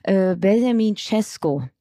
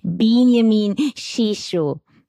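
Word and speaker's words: Benjamin Šeško: Und dann habe ich ja versucht, dich zu korrigieren und habe gesagt, er heißt Benjamin Cesco. Mhm. Auch das ist nicht korrekt.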